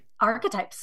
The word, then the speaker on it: archetype: So it's a hard CH. So it's archetype, like architect.